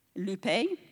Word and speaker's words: lupe: The Greek word for this is loup, L-U-P-E, loupe, which means to cause grief or to make sorrowful.